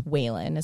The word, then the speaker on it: Whalen: So the actor's name is actually Sean, I think it's Whalen is how you say it. It's W-H-A-L-E-N.